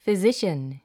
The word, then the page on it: physician: fizíʃən; フィジシャン